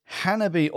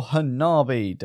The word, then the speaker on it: Hanabi: Moving on to number 4 now is Hanabi, or Hanabi, depending on which part of the world you are from as to how you say it.